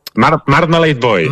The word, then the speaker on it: Marmalade Boy: Marmalade Boy.